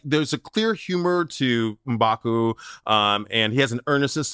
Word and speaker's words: M'Baku: Come on, that's a M'Baku. That's how they said it. You gotta be in the throat, you know.